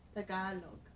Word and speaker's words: Tagalog: Tagalog.